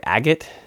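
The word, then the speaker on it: Agott: the most prominent other one is am i saying this right agate a G O T T. I think so. It reminds me of the stone but it's spelled differently, I think.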